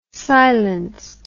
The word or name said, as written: silenced